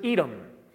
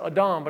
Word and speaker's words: Edom: because this was an Edom. Or you could pronounce it Adam, but I'm going to pronounce it Edom for the sake of us who speak English first and not Hebrew or Arabic or any of the other languages spoken during this time period in this area, in this region.